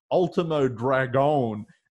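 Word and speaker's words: Último Dragón: And then he pronounced his name the proper Mexican way, Ultimo Dragon.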